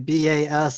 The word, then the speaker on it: Bastiat: The book, it's Frederick Bassier's The Law, and his last name is French, so it's actually Bastiat. So I believe it's pronounced Bastiat.